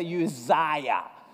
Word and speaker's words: Uzziah: Everybody say Uzziah. There you go. Say it like an Italian.